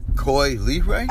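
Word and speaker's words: Coi Leray: So, one of our favorite new artists, Coley Ray. Did I say her name right? Coley LeRae? – Coy Lee Ray? – Okay, thank you, thank you.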